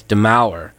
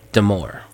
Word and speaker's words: Demaur: We played new music by this musician a while back named, I mispronounce his name as Demour, but it's really Demour. – Oh, that's good to know. – Demour. – Okay, got it. – It's spelled D-E-M-A-U-R.